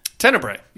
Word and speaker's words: Tenebrae: Uh, a movie I had never seen before, and I may butcher the name, uh, Tenebrae? – Tenebrae, yeah.